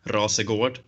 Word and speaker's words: Rasegård: My last name is pronounced Rasegård, and that is basically the same word as the region in Malmo, my hometown, where Zlatan Ibrahimovic came from, and it's a big crime spot as well in Malmo.